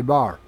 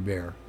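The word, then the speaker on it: Béxar: Bar, or San Antonio de Bear, depends on how you want to pronounce it, how Anglo you are.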